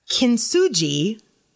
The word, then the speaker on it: Kintsugi: And it is this Kintsugi, I think is how you say this word.